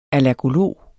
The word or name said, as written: allergolog